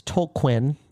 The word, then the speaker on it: Tolkien: It's Tolquin. That's how he pronounces it.